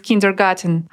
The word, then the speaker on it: Kindergarten: Хоть garten и похоже на английское garden, через букву D в этом слове, я имею в виду в kindergarten, оно сохранило исходный вариант и даже в английском пишется и произносится через T.